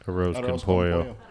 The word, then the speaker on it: arroz con pollo: How do you pronounce that? – Arroz con pollo.